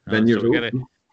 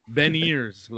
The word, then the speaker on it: Beniers: What you just did, but apparently it's Matt Beniers. – Beniers. – Beniers, like Ben Kenobi and ears. So Beniers.